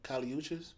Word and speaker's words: Kali Uchis: Kali Uchis.